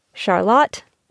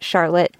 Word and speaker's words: Charlotte: A quick recap, if you only take a few names from this episode, it is Calais, not Calais, Charlotte, not Charlotte, and Wolcott, not Walcott.